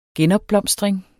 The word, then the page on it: genopblomstring: [ ˈgεnʌbˌblʌmˀsdʁεŋ ]